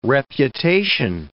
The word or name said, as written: reputation